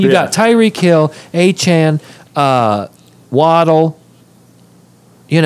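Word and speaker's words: Achane: A-Chain? Is that how you say it? – Yeah.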